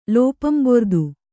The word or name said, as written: ಲೋಪಂಬೊರ್ದು